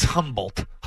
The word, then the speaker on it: Humboldt: I could watch it's a good discussion when they're talking about Humboldt, Texas, and the silent H the other night. – Humboldt. – It's Humboldt.